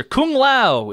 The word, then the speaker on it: Kung Lao: I'll accept that answer. Kung Lao is the official pronunciation.